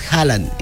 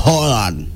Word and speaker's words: Haaland: Se llama, no es Haaland, es Haaland. – Haaland. – Es doble A. Y se pronuncia como una O. Con un tachado, una vocal que tienen los noruegos y que es Haaland.